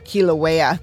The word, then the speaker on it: Kilauea: Kilauea, thank you.